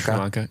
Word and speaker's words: Sri Lanka: I noticed the subtle correction of how Bryce pronounced Sri Lanka. – Sri Lanka. – Yeah. – Because Bryce says Sri Lanka twice.